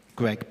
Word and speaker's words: Greig: Now, those who do Lectio 365, or those of you who like Pete Greig, is it Greig or Greg? Greg, Pete Greg.